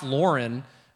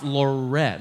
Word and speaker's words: Lauren: Now, I learned that we don't pronounce the N when saying that. So it's not Ralph Lauren, because that sounds, you know, wrong. But it's Ralph Lauren.